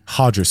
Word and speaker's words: Roger: Is it Hodger? So it's spelled Roger, but right. Hodger Gracie.